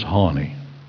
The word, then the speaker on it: Honi: His name was Hanni. That's the way I pronounce it, H-O-N-I.